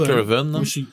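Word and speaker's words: Curwen: Mais ben, moi, j'ai tout le temps prononcé Curven.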